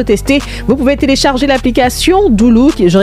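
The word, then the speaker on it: Dulu: dire que Doulou, c'est l'application qu'il vous faut.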